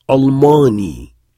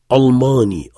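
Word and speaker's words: Almani (ألماني): Almani, et on prononce Almani. On emphatise le L. Almani.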